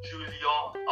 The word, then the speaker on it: Julien: Genau, aber ich habe das dann aus erster Hand erfahren, dass dem sein Name Julien ausgesprochen wird, weil der halber Franzose ist.